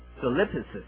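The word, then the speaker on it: solipsis (θλῖψις): 苦难这个字原文的希腊文呢，是念成这个 solipsis 啊。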